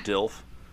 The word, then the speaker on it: DILF: The acronym is DILF. It's pronounced DILF.